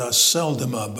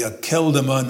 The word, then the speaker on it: Akeldama: He pronounces it a seldoma, but I'm going to tell you it's a K sound. It's a kappa in Greek, and we're going to stay with the akeldoma, not a seldoma. So if you were wondering how to pronounce it, don't pronounce it a seldoma. Pronounce it a keldoma, as if it were a hard C.